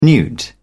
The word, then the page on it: newt: British English